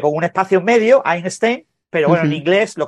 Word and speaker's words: Einstein: Ah, o sea, no viene de Einstein. – Es de una pieza. No viene de Albert. – No viene de Einstein, como se diga en alemán. No sé, cómo se pronuncia. – Einstein.